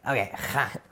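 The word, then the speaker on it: Kha (خاء): Well, Kha, when you have an itch in your throat, how do you do it? Keep going. Yep. That's it. – That's it? – Nice, like spit. – Okay, Kha.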